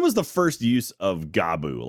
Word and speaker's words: Gabu: It's pronounced Gabu. Yes. – Uh, yes, Gabu. – Gabu. – Okay. Some people go Gabu, and I'm like, it's just.